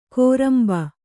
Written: ಕೋರಂಬ

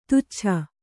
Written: ತುಚ್ಛ

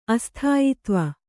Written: ಅಸ್ಥಾಯಿತ್ವ

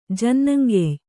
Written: ಜನ್ನಂಗೆಯ್

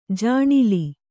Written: ಜಾಣಿಲಿ

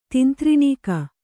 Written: ತಿಂತ್ರಿಣೀಕ

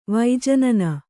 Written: ವೈಜನನ

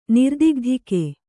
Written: ನಿರ್ದಿಗ್ಧಿಕೆ

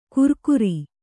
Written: ಕುರ್ಕುರಿ

